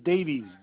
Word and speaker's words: Davies: First name is Chris, C-H-R-I-S. Last name is Davies, D-A-V-I-E-S.